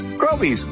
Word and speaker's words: Groby's: Groby's, where the accent is on grow.